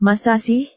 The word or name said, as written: masa sih